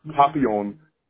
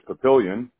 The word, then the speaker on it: Papillion: And then this one will be funny because it's a French word for butterfly, which would typically be pronounced papillon, but because we're white and Midwestern, it's papillion. P-A-P-I-L-L-I-O-N.